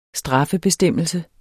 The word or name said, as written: straffebestemmelse